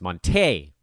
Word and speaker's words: Monte: I mean, is Monte Morris? Sorry, it's Monte. Get that right. Got to put the accent on the right syllable.